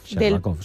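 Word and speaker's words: Sherbakov (Щербаков): El candidato, a ver si lo digo bien, Yaroslav Sherbakov, Sherbakov, sí. Muy bien.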